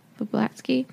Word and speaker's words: Vablatsky: How do you say this? Vablatsky? – Yeah. – Vablatsky.